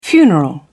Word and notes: funeral: British English

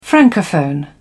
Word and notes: francophone: British English

